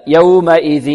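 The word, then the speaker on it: يومئذٍ: وكذلك يحذر الطالب من أن يأتي بغنة في حركة الذال أو في الذال، ينطقها بصوت من الأنف، يومئذ إذي، يومئذ، يبدأ من الهمزة أحيانا، يومئذ، لا، أو من الذال، يومئذ، لا، لابد أن ينطق الذال من طرف اللسان مع طرف الثنايا العليا،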